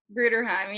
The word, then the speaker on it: Bruderheim: Now, I'm probably going to butcher this, but I know you're from, is it Bruderheim, Alberta? – Bruderheim, yeah.